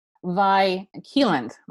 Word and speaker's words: Vi Keeland: yeah, and, and you co-write with um Vi Keeland.